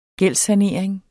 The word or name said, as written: gældssanering